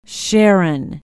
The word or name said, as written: Sharon